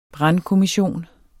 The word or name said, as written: brandkommission